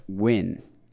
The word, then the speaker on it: Nguyen: Pronounced Win.